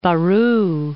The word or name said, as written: Baru